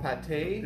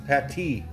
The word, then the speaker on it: Pâté: Hotel Pâté? – It doesn't matter. No, you're saying it wrong again. Pâté. – Pâté.